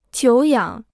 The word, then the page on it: 久仰: jiǔ yǎng